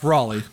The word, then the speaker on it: Raleigh: I'm just curious, is it pronounced Raleigh or Rally? – It's Raleigh.